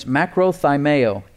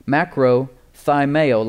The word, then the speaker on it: makrothymeo: And in the Greek, the word suffereth there is, if you're taking notes, makrothymeo, M-A-K-R-O, macro, spelled with a K in the middle, macro, thymeo, like thyme. Ladies use thyme as a spice, right? T-H-Y-M-E-O. Macrothymeo.